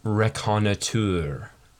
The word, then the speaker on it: reconnoitre: But they made a point of saying that the way that the proper way to pronounce this, which I don't really agree with, is reconnoitre, because they're like, well, it's a French word, and that you should say it that way.